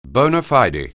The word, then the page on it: bona fide: anglais